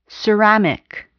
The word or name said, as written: ceramic